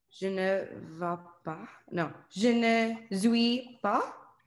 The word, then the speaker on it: non: Non. – So the N here is silent. Okay, so it's like non.